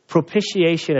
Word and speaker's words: propitiation: It took me like 12 years to say it right. It's like, what is it? Propitiation. And it simply means satisfied.